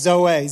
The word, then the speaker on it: Zoe: It's actually pronounced Zoe, Z-O-E.